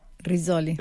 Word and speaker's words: Rizzoli: Rizzoli. – Rizzoli, ya, si bien italiano. ¿Pero es una editorial europea o neoyorquina? Porque el lanzamiento fue en Nueva York, ¿no? – O sea, Rizzoli es italiano y tiene sede en como en las capitales del mundo, y esto fue publicado por Rizzoli New York.